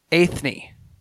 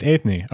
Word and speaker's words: Eithne: Oh, I have notes on her pronunciation. It's Eithne. – Oh, it's Eithne. Okay. – Eithne, yeah.